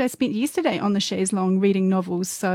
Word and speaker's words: chaise longue: Chaise Longue. Not Chaise Longue, which is how I've always been mispronouncing it. But yeah, now I'm committed to saying Chaise Longue.